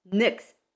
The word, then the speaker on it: Nyx: Oh, so it's Nyx, N-Y-X, but it's pronounced Nyx. – Nyx.